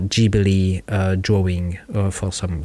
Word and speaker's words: Ghibli: But in Japanese, it's Ghibli, Ghibli Studio.